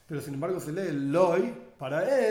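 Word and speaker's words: Loy (לא): Y en la práctica se pronuncia exactamente de la misma manera, Loy,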